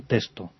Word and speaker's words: texto: Y en este caso, en España, la pronunciación normal y la más recomendable es solamente una S. – Simplificarlo. – Simplificarlo. Aunque se escriba texto, lo normal es decir un texto.